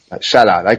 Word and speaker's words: Salah: The Egyptian, as he's pronounced, Salah.